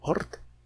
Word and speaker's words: Oort: Luego viene todo el, conjunto de, planetas enanos, de objetos transneptunianos, el cinturón de Kuiper, la eh, eh, esfera de Oort, se escribe con doble O, Ort.